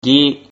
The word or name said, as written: gi